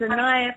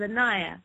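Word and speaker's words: Anaiya: And also, as Cindy was saying, you can find me on Facebook, and the name is Anaya Prakash.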